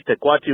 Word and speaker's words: Pequatchie: Anyway, Pequatchie Motorsports, Pequatchie spelled it phonetically for a Spanish word that means possum.